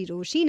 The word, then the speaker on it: Rochie: मेरा नाम है मिर्ची रोशी, नाम की स्पेलिंग है ROCHIE, and while you find me, मुझे अक्षय मैक्सिमस ने अपना नंबर भेजा.